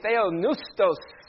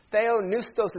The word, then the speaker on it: Theopneustos: Everybody say Theopneustos. The P is silent. Theopneustos is actually a compound word, two Greek words put together.